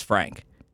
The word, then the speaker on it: Phreighnque: Yeah, but I was going to spell it P-H-R-E-I-G-H-N-Q-U-E. Yep, one more time. P-H-R-E-I-G-H-N-Q-U-E. So phonetically, it's Frank.